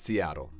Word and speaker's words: Seattle: Right, so you might notice there that there are two T's in that word, and no native speaker is going to pronounce either of them. It's Seattle.